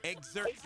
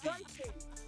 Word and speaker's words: Xerxes: Who is the how you pronounce Xerxes. Xerxes.